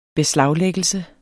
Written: beslaglæggelse

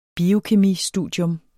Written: biokemistudium

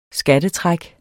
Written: skattetræk

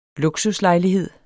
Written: luksuslejlighed

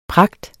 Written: pragt

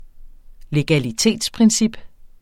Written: legalitetsprincip

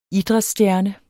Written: idrætsstjerne

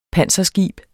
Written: panserskib

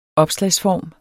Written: opslagsform

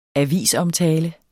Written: AVISOMTALE

